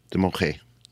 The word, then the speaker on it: Damojh: The Directorate General of Civil Aeronautics, the DGAC, said it would conduct an extraordinary verification of Mexico City-based Global Aerolíneas Damoj, D-A-M-O-J-H, or Global Air, and ground the carrier during the check. – It's pronounced like an H. Damoje.